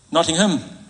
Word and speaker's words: Nottingham: Is it Nottingham or Nottingham? Nottingham. Nottingham. I was halfway between the two.